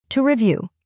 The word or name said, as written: to review